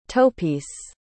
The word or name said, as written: toepiece